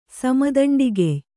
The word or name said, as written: ಸಮದಂಡಿಗೆಯ್